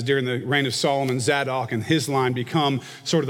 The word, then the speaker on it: Zadok: The Sadducees, this group that's mentioned here, these are people who have descended from the line of a priest named Zadok. It's spelled Z-A-D-O-K, Zadok. This is a priest that was at the time of David.